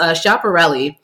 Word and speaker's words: Schiaparelli: It's Schiaparelli.